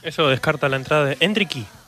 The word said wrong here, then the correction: Endrick: No me estás cerrando del todo la pronunciación. – Endrick. Hendrick, ok.